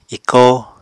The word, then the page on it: ikóo: apatani